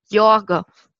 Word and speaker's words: Jorge: Jetzt habe ich, es, glaube ich, erst Spanisch und dann halbwegs möchte gern Portugiesisch ausgesprochen. Aber man schreibt es Gorge, oder? – Jorge.